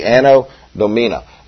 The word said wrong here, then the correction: Anno Domini: Am I saying that right? I don't know. Okay, that's close enough. A.D. People, it's not after death. Domini, thank you, that's it, Anno Domini.